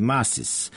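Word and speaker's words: Mascis: onda kažu da on svoje prezime izgovara zaista kao Maskis, mada se to po načinu na koje je to napisano ne bi nikad rekao.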